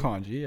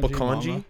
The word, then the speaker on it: Bokondji: Yeah, Can Buk- you just do it for me? Bokondji, yeah, Bokondji. Bokondji, Bokondji Yeah, that's perfect. I should have done my research before this.